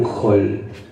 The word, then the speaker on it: hall: Y él venía del hospital, estaba yendo al hotel y termina en un hall. Y hay tres H's e implícitas tres O's para mí. Es hotel, hospital, hall. Por más que es con A, se pronuncia O.